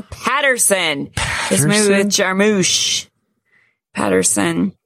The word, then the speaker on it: Jarmusch: Jarmusch.